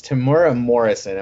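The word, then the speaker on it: Temuera Morrison: And I know I'm going to say his his name wrong, but it's it's Tamura Morrison.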